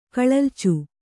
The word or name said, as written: ಕಳಲಿಚು